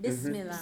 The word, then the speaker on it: Bismillah: Read the first line so it's pronounced correctly. – Bismillah ir-Rahman ir-Rahim. Alhamdulillah il-Rabbil alamin. – Beautiful. So Bismil in the name of Bismil Allah. Bismil. – Mm-hmm. Bismillah. – Bismillah. – Bismillah in the name of God, Rahman ir-Rahim.